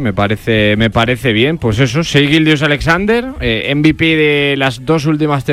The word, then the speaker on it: Shai Gilgeous-Alexander: Shai Gilgeous-Alexander. – Shai Gilgeous-Alexander.